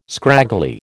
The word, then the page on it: scraggly: American English